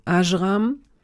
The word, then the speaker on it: Ajram: Ajram.